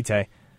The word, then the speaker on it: Tite: Also, we gonna talk about how I'm not even entirely sure on the pronunciation of this guy, but Tite, is that right? – Yeah, Tite.